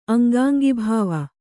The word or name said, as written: ಅಂಗಾಂಗಿಭಾವ